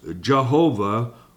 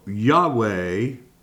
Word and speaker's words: Yahweh: When the King James translators translated the Old Testament, and when faced with translating the Hebrew word Yud-Heh-Vav-Heh, which is alternatively pronounced Jehovah or Yahweh, they employed the practice of translating that Hebrew word, Yahweh, to capital L-O-R-D.